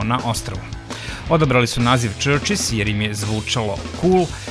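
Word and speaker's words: CHVRCHES: A da bi bilo lakše da ih pronađete prilikom internet pretrage, odlučili su da slovo U zamene slovom V, ali se u izgovoru ništa ne menja, dakle Churches.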